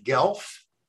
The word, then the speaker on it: Guelph: And he and the entire department at the University of Guelph. Is that how you pronounce that, Tim? – Yeah, pretty much.